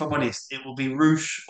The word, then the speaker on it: Rush: Roosh, but it's spelt Rush.